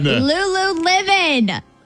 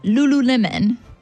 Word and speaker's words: Lululemon: Like the fruit, lemon, and then add Lulu, not Lululemon. – Really? I botched it after all that practice, and I botched it. – It's not as fancy as you think it is. It's just Lulu. It's pronounced how it's spelled.